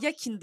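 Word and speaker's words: Joaquin: Aynen Yakin Phoenix Bey. Hakin mi? Aynen Yakin diye okunuyormuş.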